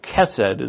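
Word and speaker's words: chesed (חסד): And so, Ruth is actually protecting her, and her heart, in which she has that chesed, chesed is really how it's pronounced, the loving kindness, it's really what's important here.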